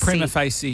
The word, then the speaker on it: Prima Facie: Prima Facie, yeah.